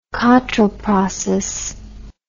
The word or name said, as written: Cottrell process